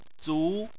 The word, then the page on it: zu: 2